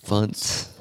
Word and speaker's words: Fonts: F-O-N-T-S. – Funtz. – Funtz. You know, a font. Like a Helvetica. – Not that it rhymes with Ariel. – Hunt. – Comic Sans. – Or punt.